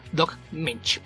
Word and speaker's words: Moench: Otro escritor de un apellido inusual por acá en tierras de habla hispana es Doc Moench, que no es Moench, sino que la O no se pronuncia, es Doc Moench. La forma correcta de pronunciar. Logren de cuenta que la O no existe, Doc Moench.